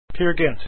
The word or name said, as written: peergynt